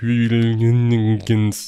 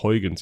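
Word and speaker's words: Huygens: Die scheiß Sonde heißt nicht Hügelgens, sondern die spricht sich komischerweise einfach Heugens, jedenfalls laut Dreisatz Nano.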